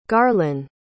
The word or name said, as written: garlan